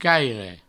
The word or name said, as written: caire